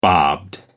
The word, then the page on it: bobbed: American English